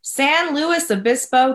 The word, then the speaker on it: San Luis Obispo: San Luis Obispo, California.